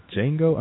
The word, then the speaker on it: Django: And I also saw Django Unchained. – The D is silent.